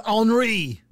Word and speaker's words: Henri: It's spelled H-E-N-R-I. – Oh, Henri. – But it's Henri. – Henri. – Henri. – Hey, you know what? You can call it Henry.